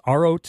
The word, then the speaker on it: Aro: Is it Aro? A-H-R-O is how it sounds, but I know it's A-R-O. – It is Aro.